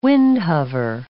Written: windhover

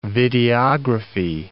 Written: videography

